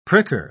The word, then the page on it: pricker: British English